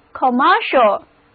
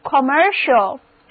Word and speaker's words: commercial: 同样的，如果我们在 commerce 后面把 e 去掉，加上 il 的形容词后缀 commercial, 注意字母 c 的发音变了，变成 sh, 那么单词 commercial 就读成 commercial, read after me, 就是 commercial, 商业的、商业化的。